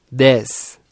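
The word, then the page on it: 10: Brazilian Portuguese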